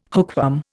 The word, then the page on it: hookworm: Indian